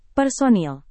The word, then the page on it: personil: Indonesian